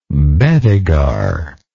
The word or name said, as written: bedeguar